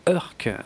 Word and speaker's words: Urk: Et, euh, et, pour une fois, et c'est très rare hein, avec, euh, avec cette langue, euh, je, suis sûr de la façon dont ça se prononce. Alors déjà parce que ça s'écrit juste URK, hein, URK mais, mais aussi parce que j'avais trouvé une anecdote qui se disait que ça se prononçait exactement comme la fin de Dunk Urk, en fait.